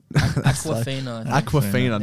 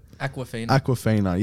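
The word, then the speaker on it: Awkwafina: Akinfenwa. – Aquafina, not the soccer player. Akinfenwa. Arbayo Akinfenwa. Sorry, how do you say it? – Aquafina.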